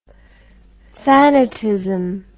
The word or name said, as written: thanatism